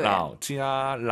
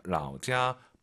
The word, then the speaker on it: 老家: 라오지아, 라오지아. 삼성일성은요, 선생님을 떠올리세요. 라오시 발음하고 똑같잖아요. 라오지아 발음이 똑같은 게 아니고 성조가 똑같은 거죠.